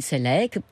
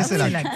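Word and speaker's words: Ceylac: Alors, Catherine Sellac et Bernard Pivot, vous préférez une question de qui? – Eh bien, prenons Catherine Sellac pour être... – Sellac, elle est très sélecte, mais c'est Sellac.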